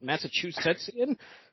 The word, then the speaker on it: Massachusettsian: I'm not even sure I know how to say it. Massachusettsian? – Yeah, you got it.